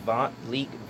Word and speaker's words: Vont Leak: And my YouTube is, my YouTube, Twitter, and Instagram are my name, Vont Leak, VONTLEAK.